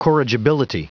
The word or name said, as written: corrigibility